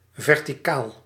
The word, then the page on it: vertical: France; [vɛʁ.ti.kal]